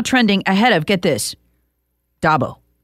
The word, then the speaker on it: Dabo: Say it with me. Dab-o. – Oh, okay. Dabo. – Dabo. Dabo.